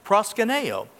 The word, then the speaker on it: proskuneo: And I'm pretty certain that's the right pronunciation because I actually went to Pastor Brian to ask him that, and he, he helped me with that. Proskuneo.